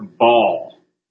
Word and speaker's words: Baal: If you don't believe God's judging America, listen, all I could see is the people bowing before Baal, B-A-A-L, or Baal as some people pronounce it.